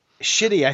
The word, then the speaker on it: Schiti: S-C-H-I-T-I. Shitty, I think, is the way that's pronounced.